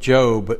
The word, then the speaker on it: Job: Turn to the book of Job, if you would, please. It's spelled the same way as Job, J-O-B.